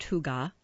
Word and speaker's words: hygge: It's pronounced hygge. It's spelled H-Y-G-G-E.